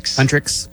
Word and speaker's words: Huntrix: but Huntrix.